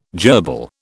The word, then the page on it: jerbil: British English